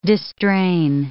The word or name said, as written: distrain